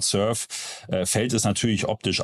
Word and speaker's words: Surfe: Äh, die Firma spricht sich Surf aus.